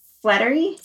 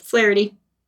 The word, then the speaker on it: Flaherty: Lynn Ahrens, and music by Stephen Flattery. – Flaherty.